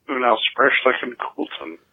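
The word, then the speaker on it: Unaussprechlichen Kulten: Unausbrechlichen Kulten.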